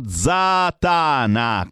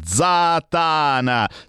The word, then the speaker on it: Zatana: Loro si chiamano Zatana con l'accento sulla seconda A. Zatana.